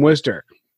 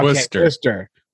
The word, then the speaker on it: Worcester: Well, we're in Worcestershire. Nope. We're in Worcester. – Okay, Worcester. – Fine. Worcester. – As long as you pronounce it funny, we'll count that.